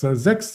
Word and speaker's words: Sachs: Ich habe es schon angedeutet, das war Goldman Sachs. Und gestern haben wir zwei Banken gemeldet. Wir wollen uns beide mal anschauen. Aber erstmal möchte ich auf Goldman Sachs, Sachs sagt man ja. Also SACHS, bitte nicht falsch verstehen, falls ich das falsch ausspreche, die kamen gestern gehörig unter Druck.